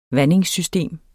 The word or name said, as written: vandingssystem